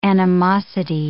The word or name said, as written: animosity